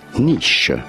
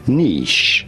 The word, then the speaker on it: niche: In French, it is said as niche. In British English, the pronunciation is similar to the French one, and it is said as niche. Niche in British English.